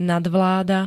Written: nadvláda

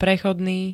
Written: prechodný

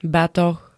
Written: batoh

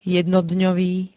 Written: jednodňový

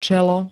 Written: čelo

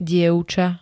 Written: dievca

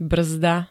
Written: brzda

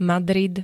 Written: Madrid

